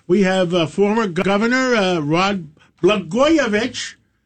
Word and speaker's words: Blagojevich: Well, Blagojevich rhymes with Katz and Matidis.